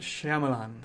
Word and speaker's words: Shyamalan: All right, so directed by, you can say his name, Shyamalan? – M. Night Shyamalan.